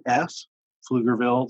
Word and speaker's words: Pflugerville: And you can find us online at wayoflifepf, Pflugerville.